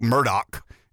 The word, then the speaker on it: Murdaugh: But one of the stories that I have been infatuated with is the um, I mean, it's spelled Alex Murdaugh. It's pronounced Alec Murdoch in the in the low country of South Carolina.